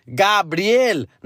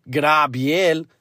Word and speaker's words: Gabriel: It's Gabriel, not Grabiel.